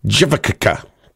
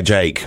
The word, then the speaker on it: JVKE: I don't really understand this marketing, but Jake is J-V-K-E, which kind of makes it... I'm trying to pronounce it correctly. How do you pronounce that? Go on, tell me, how would you pronounce that? J-V-K-E. Jivakaka. Do you have Jivakaka? Jivakaka. Well, he goes by Jake.